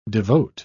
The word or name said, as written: devote